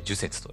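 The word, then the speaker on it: 寿雪: こことぶきに雪と書いて呪雪と読むらしいです。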